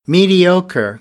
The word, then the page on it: mediocre: American English; ɚ